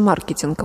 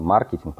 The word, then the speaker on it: маркетинг: И, ну, если переводить слово с рассматривать его как, как заимственное, то заимствованное это правильно маркетинг, потому что маркет. – маркет. Mm-hmm. Да, но правила русского языка говорят про маркетинг, потому что ударение в, в правилах русского языка как раз попадает. Э, в, в общем, маркетинг.